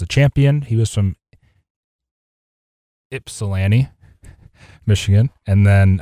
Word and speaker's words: Ypsilanti: You don't pronounce the Y. Ypsilanti.